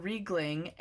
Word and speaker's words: Riegling: Do you know how to pronounce the last name? – I was going to give it a go and say Riegling. – Yeah, so Owen Riegling, and I like this song.